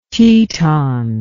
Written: teton

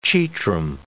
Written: chetrum

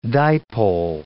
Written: dipole

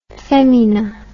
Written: femina

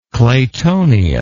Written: claytonia

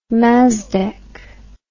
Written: mazdak

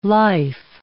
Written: life

